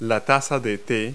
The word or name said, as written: teacup